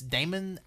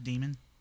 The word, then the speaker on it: Daemon: I actually think it kind of over delivered in that because we saw this Daemon X Machina game, which is just pronounced Daemon. – Is it? – Yeah, D A E. This D.